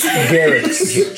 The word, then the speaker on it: Garrix: Garrix. Jerry. You misspoke. It's Garrix. – Garrix, the hard G.